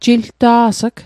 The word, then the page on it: jilta's'g: Mi'gmaq